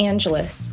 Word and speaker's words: Angelis: No, it's actually Greek. It used to be Angelidis, but they changed it in Ellis Island. But it's just Angelis, like Los Angeles.